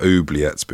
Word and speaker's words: oubliette: We've definitely talked about oubliettes before because I didn't know how you said it. – I think it's oubliette with the stress on the final syllable.